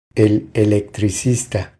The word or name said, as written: El electricista